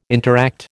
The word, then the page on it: interact: American English